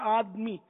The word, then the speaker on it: آدمی: تو بہرحال تو ہم آدمی ہیں, آدم علیہ السلام سے نسبت رکھتے ہیں, وہ آدمی بولتے ہیں آدمی. تو دال ساکن نہیں ہے, یہاں دال پر زبر ہے, آدمی صحیح تلفظ.